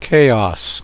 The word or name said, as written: chaos